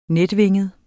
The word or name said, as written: netvinget